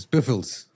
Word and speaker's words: Spitalfields: Spider, Spitalfields. Creo que se pronuncia. Spitalfields. Spitalfields. Creo que Spital, sí. Spitalfields. – No sé, Spitalfields. – No, seguro es Spitalfields.